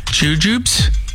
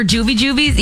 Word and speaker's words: jujube: But they're actually spelled J-U-J-U-B-E, Jujubes. – Say that again. – J-U-B-E. – Jujubes? – Jujubes. And that's how you're supposed to say it.